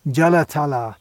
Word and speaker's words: Jalatala: Note the pronunciation. That's a long A at the end. Jalatala. This is a name of Srimati Radharani from Radha Sahasranam.